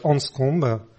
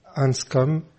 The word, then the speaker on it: Anscombe: Nous sommes réunis ce soir autour d'Elisabeth Anscombe. Alors je le prononce à la française, il faudrait peut-être dire Elisabeth Anscombe pour utiliser plus la prononciation anglophone.